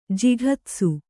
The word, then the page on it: ಜಿಘತ್ಸು: kannada